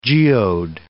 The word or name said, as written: geode